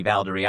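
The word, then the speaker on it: Valderi: So as you pronounced that for me, Valdery, I remember, because the chorus is kind of Valdery, Valdera.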